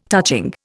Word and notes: touching: Indian

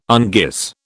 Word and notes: unguis: British English

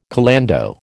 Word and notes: calando: American English

